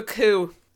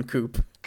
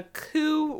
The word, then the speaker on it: coup: They're going to do a coup Riverdale. – Fuck yeah, Riverdale coup. – It is 100% impossible for me to not pronounce the P in coop.